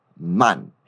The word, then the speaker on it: man: Repete comigo e presta atenção que a gente faz um som bem aberto e nada nasal quando pronuncia esse man, cara. A gente não fala man, a gente fala man. Repete comigo.